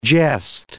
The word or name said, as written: geste